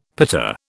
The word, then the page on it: pita: British English